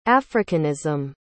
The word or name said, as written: africanism